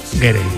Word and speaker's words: Gere: estaba pensando yo que si te apellidas Ger, bueno, ¿cómo se dice Ger o Gerre? – Gerre. Me ha hecho gracia porque has dicho Richard Gere y luego el hijo era Gere.